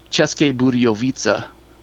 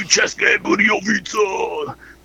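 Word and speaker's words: České Budějovice: So the town is pronounced Ceske Budějovice. – Never so would have gotten that. – I had to write it down phonetically. So instead of Ceske, it was Cheska. And I was like, all right, I got to write C-H-E-S-S like chess, and then K-A, Cheska, and then Budjovica.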